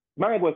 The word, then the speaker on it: Mayagüez: Is it Mayagüez or Mayagüez? – Mayagüez, yeah, perfect.